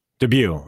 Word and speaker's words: Dubus: André, is it Dubus? – Dubieu.